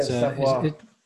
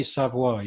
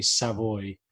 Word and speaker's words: Savoie: But, yeah, Savoie. – But you say Savoie, you'd never say Savoy.